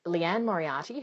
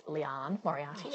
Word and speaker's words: Liane Moriarty: It turns out her name's pronounced Leigh Moriarty, I discovered after I said hi to her.